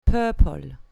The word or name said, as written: purple